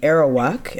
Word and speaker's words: Arawak: So I'd like to start by giving your people a land acknowledgement, which are the Arawak.